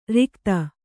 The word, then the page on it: ರಿಕ್ಥ: Kannada